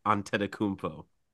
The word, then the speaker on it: Antetokounmpo: It is Antetokounmpo.